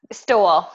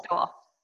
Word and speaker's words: Stowell: And Stowell, yeah. – Stowell. – Stowell. Like he stole my heart and my last name.